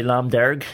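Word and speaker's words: Lámh Dhearg: You know, Love Derg, am I pronouncing that right? Love Derg? – Yeah, I was saying to Connor, they say Lam Derg in Belfast. – Lam Derg. – Lam Derg, yeah.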